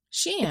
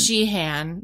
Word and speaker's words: Sheehan: S-H-E-E-H-A-N. That's like a silent H, right? – Sheehan. – It's like a Sheehan. – Yeah. – Do you say the H though?